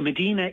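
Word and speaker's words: Mdina: Is it pronounced Medina Town? – Yeah, so Medina is a hill town.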